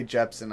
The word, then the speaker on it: Jepsen: I think we should start with Carly Rae Epsen, because, Or Carly Rae. – Of course we should. – With Carly Rae Jepsen, I'm sorry.